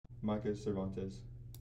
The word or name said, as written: Micah Cervantes